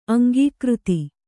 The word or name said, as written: ಅಂಕಕಾರ್ತಿ